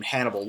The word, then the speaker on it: Hannibal: a guy named Hannibal Lecter, whose name fortuitously rhymes with cannibal, which is what he does.